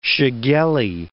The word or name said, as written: shigellae